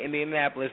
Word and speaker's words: Indianapolis: Indianapolis. – Okay, I said it right. Indianapolis.